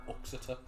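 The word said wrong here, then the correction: Uttoxeter: It's Utoxeter, actually.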